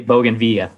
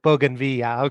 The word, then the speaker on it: bougainvillea: so I'm not sure I'm pronouncing it right. I think you said Bougainvillea. Is that right? – Everybody says it different, but different. Yeah, I say Bougainvillea. – Bougainvillea. Okay. Yeah,